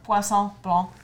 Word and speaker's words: Poisson Blanc: But Poisson. Poisson Blanc. – That how you say it? – It's whitefish. En français.